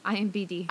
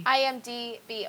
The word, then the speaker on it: IMDb: Did I say it wrong again? IMBD. – IMDb.